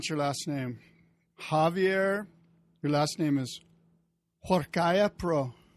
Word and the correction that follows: Huarcaya-Pro: Well, the H is silent. – Oh. How do I say it? Say it correctly. – So it's like saying war, like going to war with a kayak. Huarcaya.